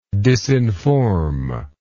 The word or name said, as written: disinformer